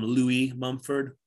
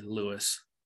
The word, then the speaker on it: Lewis Mumford: I've heard people call him Louis Mumford, but it's spelled Lewis, so I'm gonna say Lewis.